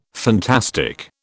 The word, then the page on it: fantastic: British English